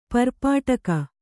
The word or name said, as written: ಪರ್ಪಾಟಕ